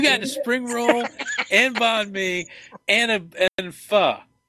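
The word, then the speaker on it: bánh mì: Yeah, and next time I get the banh mi. And, am I saying that right again? – Yeah, banh mi, that's right.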